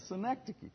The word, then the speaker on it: synecdoche: Is it a syndicke? Any English majors in there? A part that is said to represent the whole. That is what we have here. So how do you say it? Yeah, thank you. A synecdoche.